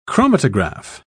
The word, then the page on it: chromatograph: British English